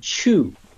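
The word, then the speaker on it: Qu: And the most important thing to happen during these ancient dynasties was the invention of something called Chu, which is spelled Q-U.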